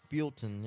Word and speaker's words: Buellton: and then you're in Bilton. – Yep. Is it Bilton or Bilton? I say Bilton. – Bilton, yeah. – I say Bilton. – Bilton, say Bilton. – Yeah. It's spelled B-U-E-L-L-T-O-N.